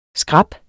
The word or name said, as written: skrap